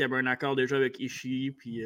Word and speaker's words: Ishii: Ishii. Ishii, c'est vrai, hein, faut que je le prononce, ce là sinon il y du monde qui ne seront pas contents.